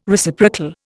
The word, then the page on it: Reciprocal: Indian